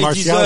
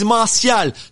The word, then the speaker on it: Martial: Say, hey, you listen to the Anthony Martial show. – He deserves everyone in the world to say his name properly. He's Martial, not Martial.